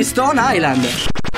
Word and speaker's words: Stonehenge: Ma perché scrivono come si legge? – Si chiama così, Stonehenge.